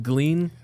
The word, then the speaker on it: Glean: Glean rhymes with clean.